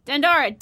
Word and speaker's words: Dandara: It's Dindara. Can you spell it? – Dandara, Dandara, which there's an actual Brazilian connection there as well, just in case you're interested.